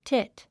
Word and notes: tit: tít; チット

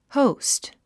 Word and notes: host: hóust; ホォウスト

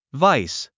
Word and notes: vice: váis; バァイス

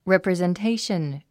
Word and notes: representation: rèprizentéiʃən; レプリゼンテーション

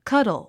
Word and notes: cuddle: kʌ́dl; カドル

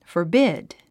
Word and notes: forbid: fərbíd; フォビッド

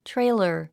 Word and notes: trailer: tréilər; トレイラァ

